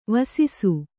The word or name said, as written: ವೆಸಸು